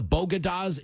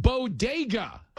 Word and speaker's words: bodega: Bogadas in the Bronx, except there's only one small problem. It's pronounced bodega.